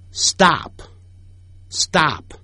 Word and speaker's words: stop: 停止 stop stop，你看有 S 或者有 T 的时候，刚才我们讲了说英语的说叫 speak， S 或者有 P 的时候会念成 but 的音， S 后头有 T 的时候会念成 D 的音 stop stop，不要念成 stop stop stop。